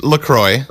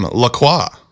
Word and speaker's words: LaCroix: So, yeah, she said, my Canadian grandmother pronounced the name LaCroix. – Okay. – LaCroix is the American way to say it.